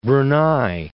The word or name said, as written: Brunei